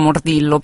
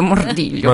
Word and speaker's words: Mordillo: Si legge Mordiglio in realtà, per cui è per quello che la gente. Sì, sì, in Italia diciamo Mordillo, però in realtà serve Mordiglio.